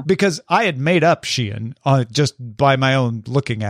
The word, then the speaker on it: Shein: It seems the pronunciation of said brand swings back and forth. But if the Times says Shein, that's what I'm going with.